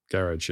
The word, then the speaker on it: garage: Garage, yeah. – UK garage. Yeah, garage. Absolutely. Yeah, only in America do they pronounce it like it's French, garage.